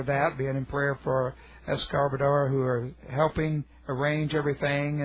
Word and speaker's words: Escobar: Their last names are Escobar, spelled, pronounced E-S-C-O-B-A-R, Escobar.